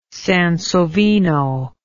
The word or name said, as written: Sansovino